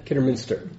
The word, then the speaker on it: Kidderminster: Kidderminster? Kidderminster.